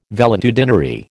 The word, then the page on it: valetudinary: American English